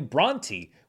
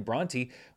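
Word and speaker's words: Brontë: Last week, I said that it was based on Emily Bronte, and apparently the author's name is pronounced Emily Bronte, which is weird because I've always heard it said Bronte, but I looked it up and it is apparently Bronte.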